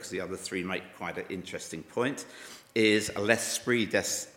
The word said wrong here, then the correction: L'esprit de l'escalier: Sorry, I should be able to pronounce this much better than that. L'esprit de l'escalier,